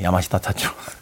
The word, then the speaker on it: 야마시타 타츠로: 그 주제곡인 레서피라는 곡을 또이 야마스터 타츠로가 불러서 히트시키기도 했었습니다. 멜리사 맨체스터와 야마스타 야마스 야야 타치로 네 야마시타 타츠로 일본 분들 발음이 참 힘드네요.